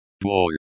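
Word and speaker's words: bol (боль): Pain slash bol. Pronounced bol, as in bowl, Russian. Bol. Pronounced bol, as in bowl.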